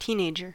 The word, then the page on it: teenager: American English